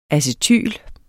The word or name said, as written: acetyl